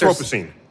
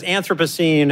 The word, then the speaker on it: Anthropocene: And we've heard this term Anthropocene. Did I pronounce that correctly? – If you're British. – Oh, so how... – There are two different... sort of competing versions. Americans Anthropocene. – There's Anthropocene and...